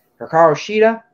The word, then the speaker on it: Hikaru Shida: Hikaru Shida, is that right? – Yeah.